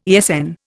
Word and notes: ESN: Indian

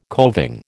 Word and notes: coving: American English

